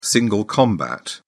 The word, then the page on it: single combat: British English